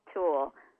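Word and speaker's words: Tulle: Oh gosh, it was called Wedding Tool, but Tool spelled like the fabric.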